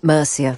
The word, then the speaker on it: Murcia: Murcia.